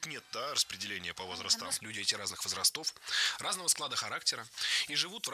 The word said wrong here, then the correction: возрасты: Возрастов, возрастам. Все это неверно. Правильно, возрасты, возрастов, возрастом, возрастах. Во всех формах сохраняем ударение на первом слоге.